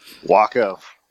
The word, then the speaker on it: Waco: Waco.